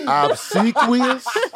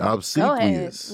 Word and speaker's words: obsequious: Obsequious? – Yes. – Did I say it right? – Yes. – Oh, you nailed that word. – Obsequious. – There it is.